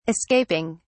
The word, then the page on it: escaping: British English